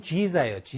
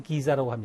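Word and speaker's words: Giza: 알 아흐람 필 기자 기자라고 발음했죠. 실제로는 지자예요. 지자. 근데 이집트식 발음으로 해보면 기자라고 합니다.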